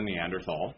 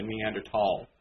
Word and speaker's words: Neanderthal: And people, I mean, when they talk about Adam, they think that he was a Neanderthal. Or I should say it properly, a Neanderthal. Apparently that's how you're supposed to pronounce it.